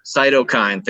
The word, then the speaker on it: cytokine: as an aside, in this case, mice, thank God, by stimulating this cytokine storm. Is that how you pronounce it? I'm sorry, my medical pronunciation is horrendous. But this is basically cytokine. – Thank you so much.